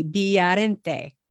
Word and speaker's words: Villarente: Again, that V, Villarente.